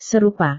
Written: serupa

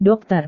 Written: dokter

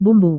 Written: bumbu

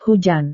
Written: hujan

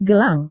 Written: gelang

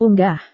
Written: unggah

